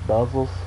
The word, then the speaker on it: Buzzas: Aaron, I'm sorry if I pronounced your name wrong, but it's B-U-Z-Z-A-S, Buzzas, Buzzles.